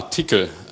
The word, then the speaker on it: Tcl: Ja, Tickel. Also schreibt man so, TCL, aber die Aussprache ist üblicherweise Tickel.